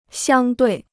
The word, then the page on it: 相对: xiāng duì